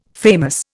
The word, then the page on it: famous: Indian